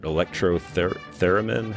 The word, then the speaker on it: electrotheremin: I, I don't know if I'm pronouncing it electrotheremin. How do you say that? – Yeah, that's that's correct.